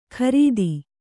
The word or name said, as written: ಕ್ರಿತ್ತಿ